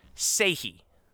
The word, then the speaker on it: Skjei: And I just want everyone to know, just so y'all can understand, the name is spelled S-K-J-E-I. Here's how it's pronounced. Sahe.